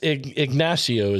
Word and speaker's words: Ignacio: And it's Ignacio is the correct way of pronouncing that, correct? – Actually kind of either or on that one, so you're fine. Okay. The locals say it both ways.